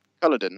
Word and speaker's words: Culloden: Culloden, that's it.